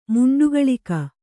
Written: ಮುಂಡುಗಳಿಕ

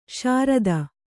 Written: ಶ್ರೋತ

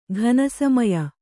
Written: ಘನಸಮಯ

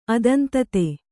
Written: ಅದಂತತೆ

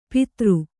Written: ಪಿತೃ

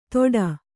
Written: ತೋಟಾ

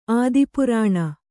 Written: ಆದಿಪುರಾಣ

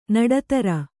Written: ನಡುದಾರಿ

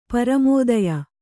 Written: ಪರಮೋದಯ

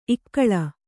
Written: ಇಕ್ಕಳು

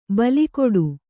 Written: ಬೆಲೆಗುಡು

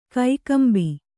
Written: ಕಕ್ಕಂಬು

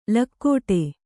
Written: ಲಗಾಟಿ

